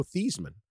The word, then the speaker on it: Theismann: I mean, if you don't, Joe Theismann, famous quarterback, obviously, especially in the NFL, was at Notre Dame, and he used to pronounce his name, Joe Theismann. And as part of the Heisman campaign, they changed the saying of his name. I mean, this is a prominent individual. – Oh, yeah. That was Roger Valdeseri, the longtime SID. Great SID. – The greatest SID. – Yes, the greatest moment in SID history. Convinced the quarterback to change his name to rhyme with Heisman.